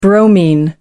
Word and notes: bromine: American English

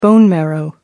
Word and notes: bone marrow: American English

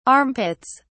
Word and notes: armpits: British English

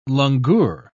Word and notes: langur: British English

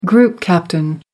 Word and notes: group captain: American English

